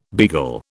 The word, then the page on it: beagle: British English